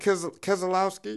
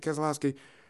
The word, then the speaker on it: Keselowski: Kevin Harvick, usually sponsored by Bush, will be sponsored by Jimmy John's, and Brad Keselowski. Is that how you say it? – Oh, come on. You watch the race with me. – Yeah, I think that's it. Keselowski.